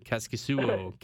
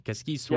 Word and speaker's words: Kaskisuo: Kaskisuo. Kaskisuo. – Yeah. – It's Kasimir Kaskisuo.